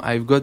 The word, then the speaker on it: Avegott: Il y a, y a, un jeu de mots, donc on a voulu ben, franciser le nom Avgot, puisque ça s'écrit A-V-E-G-O. e g o ah, pardon, AVEGO2T.